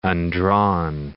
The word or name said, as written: undrawn